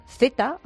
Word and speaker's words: Theta: Se llama Z, aunque se escribe T-H-E-T-A, ¿no?